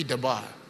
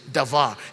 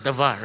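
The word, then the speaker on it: davar (דבר): Wherever you find the word, it will be dabar. Or actually, in the true pronunciation, davar. Even though it's written as D-B-A-A-R, D-A-B-A-R, it's pronounced as davar, right?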